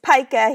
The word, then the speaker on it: pica: I think the eating disorder is known as pica. I don't know if I'm pronouncing that correctly. – It's pica here.